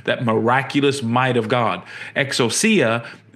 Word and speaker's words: exousia: And so that word power, or that word dominion in English, is in Greek exousia, which is or some say excusia, or there's different ways you can pronounce it, but it means authority, like the right to rule, the right.